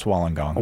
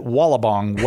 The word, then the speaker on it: Wollongong: You really should go now because you've learned to pronounce Wollongong. – Wollongong. – I believe you called it Wallabong while you were there. – Indeed, I did. – But it's Wollongong. Hey, this hour, Carlo is here with us to talk about apologetics.